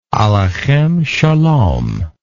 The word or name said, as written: aleichem shalom